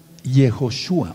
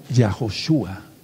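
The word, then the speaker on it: Yehoshua (יהושע): se pronuncia Yehoshua o Yahoshua.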